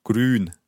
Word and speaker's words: Grün: Hard to pronounce. Grün. – Grün. – It has the Ü sound again. Grün.